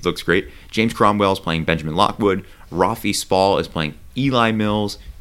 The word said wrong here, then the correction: Rafe: It's Rafe Spalls. – Rafe? – Yeah, that's how it's pronounced.